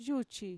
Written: yuchi

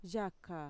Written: yaka

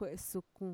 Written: kue'esukun

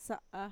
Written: sa'a

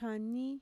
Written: kanii

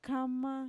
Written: kama

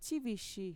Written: txivishi

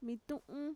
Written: mitu'un

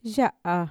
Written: ya'a

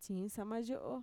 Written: txiinsamayo'o

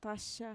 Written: taxa

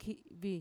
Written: ki'vi

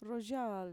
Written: royaal

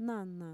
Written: nana

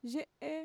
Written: ye'e

